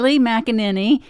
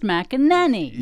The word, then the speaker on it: McEnany: Now, Donald Trump's new press secretary, Kayleigh McEnany. Easy if you think of the word ninny. – Is that actually in the name? – Spelled ninny, but it's pronounced McEnany.